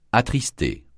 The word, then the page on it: attrister: francais